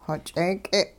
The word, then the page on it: xoch'eek'e': hupa